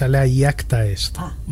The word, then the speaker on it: alea iacta est: La pronunciación correcta es alea iacta est. Más o menos. Luego podemos discutir porque hay versiones.